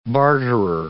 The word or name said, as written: barterer